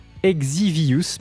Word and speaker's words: Exivious: ou Exvius, dont je vous parlais, pardon, je le prononce très très mal, Exivius, pardon, voilà, E-X-I-V-I-O-U-S, donc deux groupes dont certains membres proviennent,